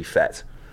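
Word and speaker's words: fête: I think fete is probably in the truest French pronunciation with the convex accent over the E. I think it would be fete.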